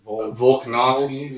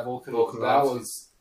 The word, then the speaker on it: Volkanovski: Volkanovski? – Volkanovski.